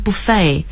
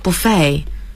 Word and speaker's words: buffet: 是这样的，我们叫 buffet，但实际上这个单词在欧美人的读法中它读成 buffet，是法语词直接过来的，最后的 t 不发音， buffet 是最时髦的读法。